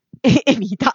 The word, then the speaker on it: Evita: Mi è venuta in mente una domanda, perché non so se voi vi ricordate del musical Evita, come si pronuncia? Evita, okay.